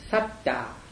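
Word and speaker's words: sattā: and that word is satta, S-A-T-T-A, long A. The second A is long A. Now, the word S-A-T-T-A with short A means a being, like bodhisattva, right?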